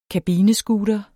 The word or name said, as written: kabinescooter